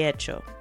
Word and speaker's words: hecho: So de hecho. And hecho is spelled with an H, so it's spelled with the letter H, even though it's silent. So de and then hecho, H-E-C-H-O.